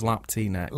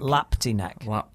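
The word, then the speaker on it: Lapti Nek: Everybody remembers Lappy Neck. And that's easy for me to say. Is that how you pronounce it? I don't know. – No, I think it's Lapty Neck. – Lapty Neck.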